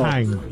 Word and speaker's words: Mannheim: De acordo com ele, a cidade de Mannheim, provavelmente não é assim que se pronuncia no meu sotaque alemão, peço perdão. – Não, tá ótimo que é o alemão. – Mannheim. – É isso aí.